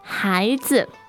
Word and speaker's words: 孩子: And 孩子, 孩子. – Okay, so the 孩 is second tone. – And the 子 is neutral tone.